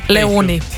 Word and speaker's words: Leoni: Ja, to je bil Alex Warren, prihaja pa pevka, ki sem jo zanč na robe izgovorila, ampak zdaj sem se naučila. – In kako je ime te pevki? – Leoni.